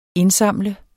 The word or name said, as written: indsamle